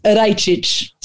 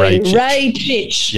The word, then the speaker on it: Rajcic: Could we just clear up exactly how it is pronounced? – Rajcic. So Rajcic.